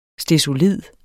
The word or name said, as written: STESOLID